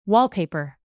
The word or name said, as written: wallpaper